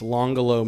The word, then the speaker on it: Langolo: L-A-N-G-O-L-O. – So Langolo. – E-S-T-A-T-E. It is Langolo in someone's language, but it's Longolo maybe in Italian, which is it's an Italian word.